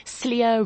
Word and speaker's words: Slier: People saying Slier rhymes with liar, and you can um, she's she's crying these false tears.